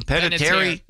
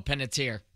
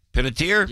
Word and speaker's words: Panettiere: Hayden, how do you pronounce her name? Hayden Panettiere? – No, Panettiere. – Panettiere? – Yep.